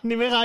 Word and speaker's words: Nimega: Nimega, ahí hice mi Erasmus yo.